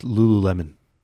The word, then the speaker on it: Lululemon: It's like I was reading about the clothing company Lululemon, right? I don't know if you know what I'm talking about at all. – It's pronounced Lululemon.